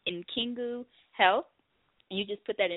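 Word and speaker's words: N'kingu: So Nkingu Health.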